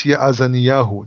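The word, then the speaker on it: Yazaniyahu (יאזניהו): Yazniyahu. – The son of Shaphan. Each man It's Yazaniyahu. It's a kind of a difficult name, Yazaniyahu.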